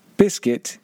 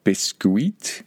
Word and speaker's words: biscuit: Biscuit is British English. And just be careful, don't pronounce that word biscuit like it is written. It is pronounced biscuit in spoken English.